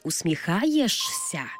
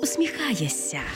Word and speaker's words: усміхаєшся: Не замислюєшся, а замислюєшся. І не усміхаєшся, а усміхаєшся. Почули різницю, так? Замість «шся» вимовляємо «ся».